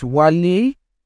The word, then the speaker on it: Wale: Once more, my name is Wale, W-A-L-E, pronounced as Wale, as you heard.